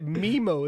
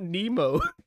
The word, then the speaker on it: Nemo: Yeah, but you called it Memo as well, not Nemo.